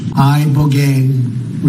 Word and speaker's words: Ibogaine: I, Bogain, remember the name. Is that pronounced relatively properly, what you said? – Yes. – I don't want to get it wrong.